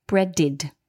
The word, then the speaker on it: breaded: And breaded, now bread ends in a d, which is this dental consonant. So the ed is pronounced id, breaded.